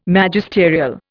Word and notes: Magisterial: Indian

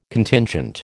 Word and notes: Contingent: American English

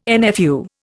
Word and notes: NFU: Indian

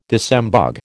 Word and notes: disembogue: American English

